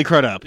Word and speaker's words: Crudup: Billy Kudrup. – It is Billy Crudup.